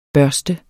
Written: børste

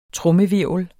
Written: trommehvirvel

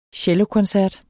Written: cellokoncert

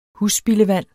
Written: husspildevand

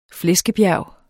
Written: flæskebjerg

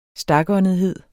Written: stakåndethed

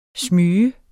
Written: smyge

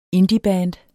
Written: indieband

